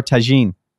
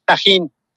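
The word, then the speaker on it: tahini: We wanted to know how to pronounce, is it tahine or tagine? – Tahine. – Tahine. – Tahine. – See, I had it. – It's a tahine with an H.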